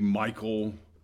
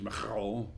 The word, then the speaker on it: Michal: This can be Michael, and as a matter of fact, it is the meaning of the name is the same as Michael, who is like God. The kind of Hebrew way to say is Michal, and I'm not good with the H.